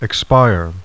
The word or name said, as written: expire